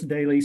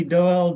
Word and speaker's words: Doelle: It's Chris Daly, spelled D-O-E-L-L-E. – So that's how you pronounce it? Daly? – Yeah. Yeah. – Oh, I had no idea. Thank you for helping me with that. – Yeah, everybody calls me Doyle, Dewey, Dolly.